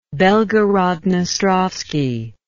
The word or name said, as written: belgorod dnestrovsky